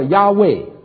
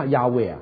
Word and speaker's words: 亚卫: 第一，父神，在希伯来文里面是四个子音 YHWH，你知道希伯来文里面没有母音的啊，发音叫做亚卫，我们中文翻成耶和华啊。那么这个我常常问我的犹太朋友，我们这个大学里面教书很多的犹太人哈，问他，我说这个没有母音你怎么发音，你发亚卫，这不是伊娃？他说对啊，我们就发音就发亚卫啊。